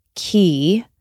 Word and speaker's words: Quay: But Q U A Y, pronounced key also from Australia.